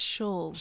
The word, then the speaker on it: Schulze: How do you say your last name? – It's Scholes.